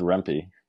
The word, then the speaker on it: Rempe: I keep just telling myself it rhymes with Kempe. So hopefully I said it right. There's a strong Rempy.